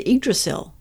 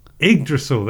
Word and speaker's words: Yggdrasil: Oh, The Return of the Yggdrasil. – Yggdrasil, that's right.